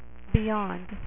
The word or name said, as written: Beyonder